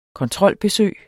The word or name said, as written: kontrolbesøg